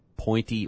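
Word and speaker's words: Pointe: with the Champ Sports Pep Rally at Pointy Orlando. I'll bet that's point because the E is silent.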